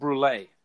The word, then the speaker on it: Brule: Are you familiar with, I'm assuming this, the name is pronounced, Dr. Steve Brule.